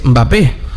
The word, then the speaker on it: Mbappé: cuando en realidad es Mbappé, como Mbeyú.